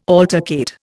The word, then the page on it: altercate: Indian